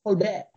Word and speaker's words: Colbert: Colbert.